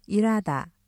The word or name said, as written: 일하다